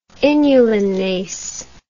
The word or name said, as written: inulinase